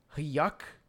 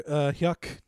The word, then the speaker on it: Huyck: Well, I guess we could, like, the director is Willard Yuck, right? – I believe that's how you say his name. Willard Huyck directed this movie and wrote it with his wife, Gloria Katz.